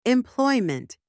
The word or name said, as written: employment